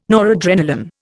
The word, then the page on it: noradrenalin: Indian